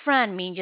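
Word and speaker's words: Franmi: My name is Fran Min. Just spell that F as in Frank. R like Robert, A like Apple, N like Nancy, M like Mary, I like Igloo. Friend me.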